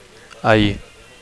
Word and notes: ahir: Catalan

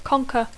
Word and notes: conquer: English

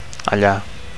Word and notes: alla: Catalan

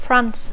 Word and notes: France: English

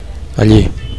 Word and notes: alli: Catalan

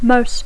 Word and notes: most: English

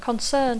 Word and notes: concern: English

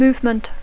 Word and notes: movement: English

